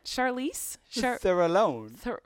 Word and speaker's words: Charlize Theron: Charlize? – Theron. – What's her name? – Charlize Theron.